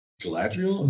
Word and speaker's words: Galadriel: for me, I went with uh, basically the whole scene with uh, Galad- Galadriel.